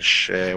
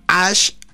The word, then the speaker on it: SHH: Es que, claro, nos han puesto una canción que se llama Ash o Ash. O, él dice Ash con una A. Pero claro, no, no hemos optado por. – Dice Ash, pero la canción es SHH.